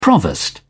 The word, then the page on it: provost: British English